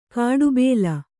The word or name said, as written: ಕಡುಬಲಿ